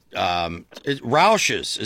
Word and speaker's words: Rausch: Rausch's is how I pronounce it. Is that how they pronounced it? Rouch. – Yeah, Rausch. Jack Rausch. – Yeah. – Pronounces it. – Yeah, right. – He spells it the same way.